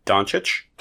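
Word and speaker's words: Dončić: Doncic? – Yeah, Luka Doncic.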